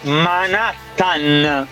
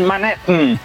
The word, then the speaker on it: Manhattan: Jr. Jackson Spagno Spannaus che si dice Manhattan, non Manhattan.